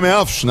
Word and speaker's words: Memphis: Bom, não é Memphis, né? A pronúncia correta. – que o empresário dele me falou aí, os holandeses falam Memphis.